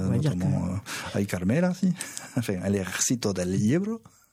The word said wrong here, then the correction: Ebro: Del Ebro, pardon. Je le prononce mal, alors que, franchement, oh là là, je la fous mal. – C'est le fleuve, El Ebro.